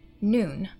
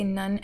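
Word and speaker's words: noon (נון): The Hebrew word for faithful one, Ne'eman, N-E-apostrophe-E-M-A-N, is signified by the letter nun, noon. Well, I say nun, N-U-N, because English is my first language, but technically you're supposed to pronounce it noon, which is kind of fun.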